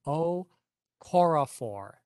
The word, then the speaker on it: Okorafor: Okorafor is how she pronounces her name.